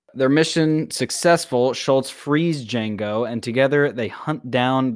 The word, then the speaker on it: Django: This week we watched Django Unchained, which is actually Django Unchained because the D is silent, hillbilly.